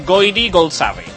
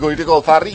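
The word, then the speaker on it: Goirigolzarri: Estem parlant de Goiri Goldsarri. – Recordem que... que Justo Molinero es va fer un autèntic nus dient ahir Goyrico el Farri i avui ha sigut mort, el torn de locutors dient això. – El torn de Juan Ramon Lucas, que també de Ràdio Nacional, que també s'ha fet un embolic considerable.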